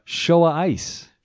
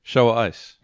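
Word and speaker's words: XiaoIce: That's it's pronounced Showa Ice. – Show ice. – Yeah. – Okay. – Show ice. – Show ice. – So it's, yeah, in, at the end of the sentence, it's got how they pronounce it. It doesn't even have an S at the start.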